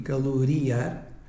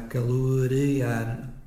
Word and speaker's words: calorear: Tenho encontrado esta forma escrita com I, caloriar, mas o certo é que a palavra deverá ser grafada com E. Caloriar é assim pronunciada, mas de facto será calorear. Calorear e não caloriar com I.